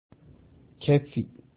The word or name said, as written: Keffi